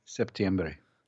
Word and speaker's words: septiembre: How do you say it in Spanish? Septombre. – Septiembre. – Septiembre.